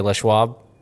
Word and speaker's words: Les Schwab: I think you say it. I think if you're from around here, you can say Les Schwab. – Oh, Les Schwab. – But just know that it's spelled L-E-S, and then I think it's S-C-H-W-A-B.